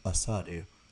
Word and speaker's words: Asadu: Nana Asadu, which, side note, I've always said his name Asidu. The depth chart comes out and it tells you the phonetic pronunciations. You pronounce his last name Asadu.